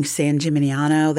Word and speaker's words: San Gimignano: I'm very excited about people seeing San Gimignano.